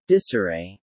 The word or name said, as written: disarray